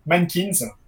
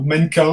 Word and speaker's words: MNNQNS: Alors, ça s'écrit M-N-N-Q-N-S et ça se prononce Mankins. Donc, euh, ou mannequin, enfin, ça dépend. Euh, je laisse choisir votre, euh, votre prononciation.